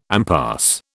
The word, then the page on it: impasse: British English